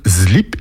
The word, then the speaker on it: slip: Au lieu de prononcer le S de slip, on le prononce comme un Z qui lui est voisé, ce qui donne slip et pas slip.